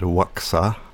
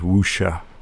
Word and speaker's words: Wuxia: I've heard people pronounce it Waxa, but we'll trust that the Chinese translation that Wikipedia has thrown at us, it is Wuxia.